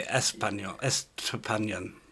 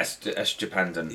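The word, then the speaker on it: Estupiñán: Estupanyan. – Yeah, Estupanyan. – Yeah, nailed it.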